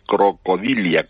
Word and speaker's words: Crocodilia: Crocodilianos, crocodil, no estoy pronunciando mal, es que científicamente se dice crocodilia, crocodila. Sí.